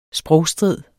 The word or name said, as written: sprogstrid